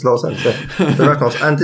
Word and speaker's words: Ravnos: It's actually pronounced Ravnar. – Ravnos. – Ravnos, yes.